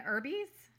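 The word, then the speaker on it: Erbe: Catherine Irby's? – I think it's Irby, yeah.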